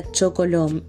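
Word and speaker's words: Csókolom: que el nombre húngaro se pronuncia Chocolom y significa justamente puedo besar tu mano.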